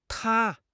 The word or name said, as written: 塌